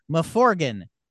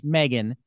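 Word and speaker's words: M3GAN: And I said, I guess the sequel will be called Mithrigan, but no, it's Megan, but it's a three. It's pronounced Megan.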